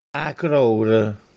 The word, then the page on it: Acròre: Castellanetano